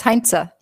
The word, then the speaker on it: Hainzer: Hainzer.